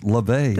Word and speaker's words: Laufey: Her name is LaVey, although you spell it L-A-U-F-E-Y. She's Icelandic. She's Chinese. She's hooked on Ella Fitzgerald. It's LaVey.